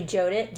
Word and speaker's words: Jyotish: So you do readings for people, for Vedic, but I'm saying it wrong. It's actually Jyotish.